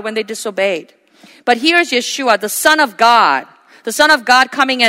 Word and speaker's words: Yeshua: So by following Yeshua, which is his real original name pronunciation, Yeshua, the Savior, following Yeshua and being yoked with him, we can enter that rest.